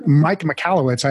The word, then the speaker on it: Michalowicz: We're here today with Profit First, Clockwork, Surge, and all sorts of other business book author, Mike Michalowicz.